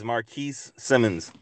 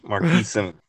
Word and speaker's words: Marquis Simmons: My name is Marquis Simmons. Marquis Simmons.